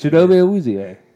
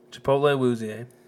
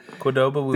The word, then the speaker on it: Chidobe Awuzie: Chipotle Awuzie. – Not Chipotle. – Chidobe Awuzie.